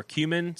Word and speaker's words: cumin: And it's cumin, right? Or cumin spiced wonton noodles. How do you say that word? What's that spice? – I'm not looking at it. – Okay. C-U-M-I-N. – Cumin. – Cumin. – Yeah, thank you.